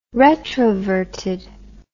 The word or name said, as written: retroverted